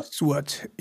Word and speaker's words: Stewart: Habe ich es richtig ausgesprochen? Stewart, oder? – Stewart, ja.